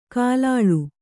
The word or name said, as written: ಕಲ್ಲಾಳ